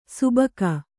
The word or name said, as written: ಸುಭಗ